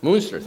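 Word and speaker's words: Münster: Or Munster, am I saying it right? Munster, thank you.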